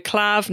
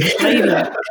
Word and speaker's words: Klaviyo: I keep having to tell, they currently sponsor the podcast, and I keep having to tell myself off every time I go Clav. No, it's Klaviyo. Klaviyo is the right way to say it.